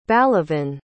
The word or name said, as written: balavan